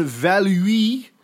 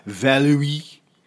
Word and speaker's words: Valluy: And General Jean-Étienne Valuy, and I'd like to thank all of our French listeners for helping me out on Facebook last night to tell me how to pronounce V-A-L-L-U-Y, Valuy, Valuy.